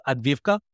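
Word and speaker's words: Advivka (Авдіївка): How do you say that town? That would help me for my future conversations. – I'm not sure I'm doing it right. So I pronounce it Advivka.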